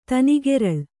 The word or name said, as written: ತನಿಗೆರಳ್